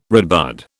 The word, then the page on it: redbud: British English